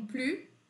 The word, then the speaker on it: plus: Eu tenho plus, que eu não pronuncio S porque é uma negativa, e eu tenho rien, que também é uma negativa.